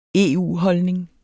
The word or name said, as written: EU-holdning